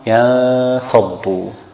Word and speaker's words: Yanfaddu: So how do we pronounce it? – Yanfaddu. – Yeah.